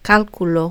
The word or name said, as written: calculo